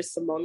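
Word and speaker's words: Cymone: I'm on Facebook under Simone Lachey. My name is spelled with a C, C-Y-M-O-N-E.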